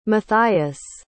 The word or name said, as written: matthias